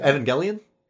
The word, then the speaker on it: Evangelion: Evangelion? – Yeah. – Oh, okay. – Oh, is that how you pronounce it? – No, you pronounce it correctly.